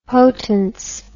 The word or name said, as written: potance